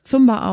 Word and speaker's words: Zumba: Manche sprechen das Zumba aus. Landläufig oder weitläufig wird es allerdings Zumba ausgesprochen.